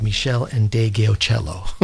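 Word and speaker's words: Meshell Ndegeocello: And I thank Brian Saunders for the correct pronunciation of Michelle and De Gaocello.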